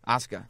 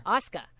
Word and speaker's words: Asuka: Yeah, Asuka. – Asuka. I always call it Asuka. – Well, it's spelled Asuka, but Asuka.